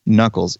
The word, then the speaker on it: Nuckels: I am Nuckels, NUCKELS, at right.